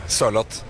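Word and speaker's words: Sørloth: How are you supposed to say Sorlot? Sorlot? How do you say that? – A bit slower. Sorlot.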